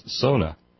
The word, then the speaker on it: sauna: And as a Finn, I think you should know the proper pronunciation of sauna is sona.